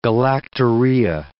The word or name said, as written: galactorrhea